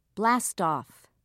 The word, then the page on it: blastoff: British English